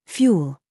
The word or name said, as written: YOU